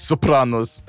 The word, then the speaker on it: Sopranos: Am I saying that wrong? Everyone always makes fun of how I say it. Sopranos, Sopranos. – Tomato, tomato. – It's pronounced Sopranos.